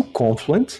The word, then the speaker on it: Confluent: Eu, eu pronuncio Confluent porque é como eu ouço da boca dos, do, dos donos da empresa, né?